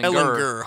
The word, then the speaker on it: Ehlinger: Ehlinger, Ehlinger hard G baby.